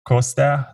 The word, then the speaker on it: Costea: Yeah, so On Twitter, my name, my screen name is Vlad Costa. That's how it's pronounced. So C-O-S-T-E-A.